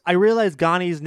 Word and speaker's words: Ghani: Okay, so this chapter, Paul possesses Leto, and Chani possesses Ganima, and Paul I realize Ghani's nickname rhymes with Chani. Ganima's nickname is Ghani. It's Ghani and Chani.